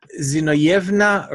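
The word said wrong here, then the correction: Zinovyevna: Rosenbaum. – Ah, okay. – Zinovevna, sorry. – Okay, Zinovevna, yeah.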